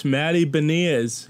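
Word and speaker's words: Matty Beniers: Greg, you got to say it right. It's Matty Berniers.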